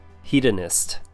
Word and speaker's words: hedonist: And our last one for pronunciation tips would be hedonist. Three syllables. He, as in the word he. D, short U sound. And nest. Sounds like nest, kind of. Hedonist.